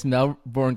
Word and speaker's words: Melbourne: The Australians are gonna thump you on your pronunciation of Melbourne. There's no R in that word. You might see it, but it's not pronounced.